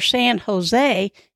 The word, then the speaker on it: San Jose: They often drop the letter J used in words and replace the J's with the letter H or the H sound. For example, you would pronounce those two cities you want to visit, San Jose, or San Jose, and La Jolla."